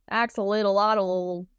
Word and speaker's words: axolotl: Axolotl. I'm going to be honest. I had no idea how to pronounce that until you said it.